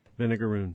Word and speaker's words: vinegaroon: Now, I got some stuff to say about the Vigaroons. Is that how you pronounce it? How do you say it? – Vinegaroon. – Vinegaroon. Like if it were shooting ketchup instead of vinegar, it'd be ketchup-a-roon.